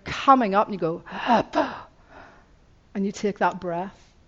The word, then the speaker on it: Abba: And it's pronounced as Leif Hepburn, A-B-B. It's like, Abba, Abba.